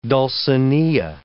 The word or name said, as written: Dulcinea